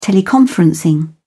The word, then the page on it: teleconferencing: British English